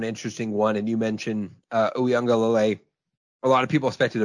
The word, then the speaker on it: Uiagalelei: DJ Uiagalelei. I always screw up his name. – Uiagalelei. – Uiagalelei. That's right. I always forget Beautiful. That extra syllable.